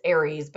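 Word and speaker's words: Ares: Well, it's Aries, but they pronounce it Eris in Spanish.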